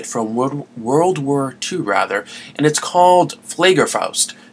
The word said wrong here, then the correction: Fliegerfaust: And I'm sure I ruined that, and you would think, being German myself with my German heritage, I'd be able to pronounce it correctly. Fliegerfaust might be a better pronunciation,